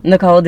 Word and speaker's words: Nikole: Nikole, Denise.